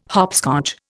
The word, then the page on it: hopscotch: Indian